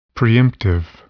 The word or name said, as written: preemptive